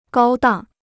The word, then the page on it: 高档: gāo dàng